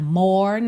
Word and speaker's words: more: É claro que se você quiser, pode cantar more night, ok? O importante é reparar que o E final é mudo, more.